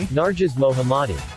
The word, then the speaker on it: Narges Mohammadi: Narges Mohammadi.